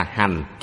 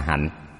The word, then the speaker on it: hạnh: Nhưng hiện thời có rất nhiều người đọc là hành, chúng ta đọc là hạnh.